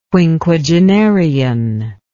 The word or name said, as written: quinquagenarian